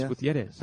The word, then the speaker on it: Gutierrez: Gutierrez, is that the proper way to say his name? – Spanish. It's Gutierrez.